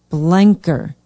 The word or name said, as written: blenker